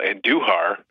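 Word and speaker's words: Andújar: I've been extremely pleasantly surprised by the rising of Miguel Andujar. That's how you pronounce his name. It's not Andujar. It's Andujar. Mm.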